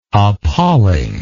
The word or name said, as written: appalling